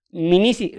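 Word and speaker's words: Dmanisi: Minisi, aunque se escribe primero con de, como Minisi.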